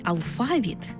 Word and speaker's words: алфавіт: Наголос у слові «алфавіт» на другому складі основний у нашій мові, бо збігається з грецьким «алфавітон», від якого і походить українська лексема.